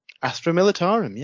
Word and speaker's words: Astra Militarum: Yeah, so for the, obviously we've got the new Astra, I can never pronounce it correctly, Astra Militarum. – Astra Militarum, yeah.